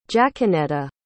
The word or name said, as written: jacquenetta